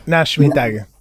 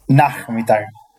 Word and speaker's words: Nachmittag: ¿Cómo se pronuncia eso? Nachmittag. Nachmittag. como, con J.